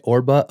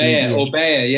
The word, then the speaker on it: Orbea: How do you say it? Orba, O-R-B. – Orbea, yeah, the Spanish, Orbea.